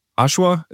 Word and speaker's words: Oshawa: And I'm fairly reasonable, hopefully, that, look, I know that bivocational, I kind of using Oshawa, that's how you say it? – Yeah, yeah.